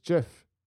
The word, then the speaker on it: GIF: It's a soft G pronounced GIF.